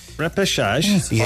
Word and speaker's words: repêchage: R-E-P-I? – Repêchage. R-E-P-E-C-H-A-G-E.